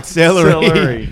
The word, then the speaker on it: celery: It was more closer to like celery. – I said salary. – And then you went, celery.